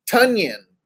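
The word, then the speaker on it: Tonyan: Have ton have have ton, Toyon and I, have been recently told that it's pronounced Tunyon, almost like Funyon is what they referred it to.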